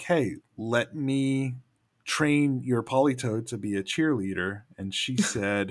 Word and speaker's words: Politoed: When I was younger, I always pronounced it polytoid, like hemorrhoid. – Now I'm back in. – Misty had one. We were in our dark moments for Pokemon watching when she had a Politoed.